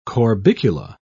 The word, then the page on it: corbicula: British English